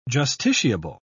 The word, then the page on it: justiciable: British English